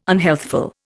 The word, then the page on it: unhealthful: Indian